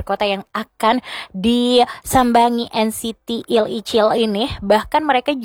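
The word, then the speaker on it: Il Ichil (일이칠): So next teman Perudu, di hari yang sama juga nih, NCT Il Ichil nih, kalau cari tahu ternyata bacanya tuh NCT Il Ichil. Tetap aja 127 ya teman Perudu, tapi dalam bahasa Koreanya.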